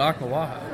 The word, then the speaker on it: Oklawaha: It's not Okla? – Everybody, all the locals I talked to said Oklawaha.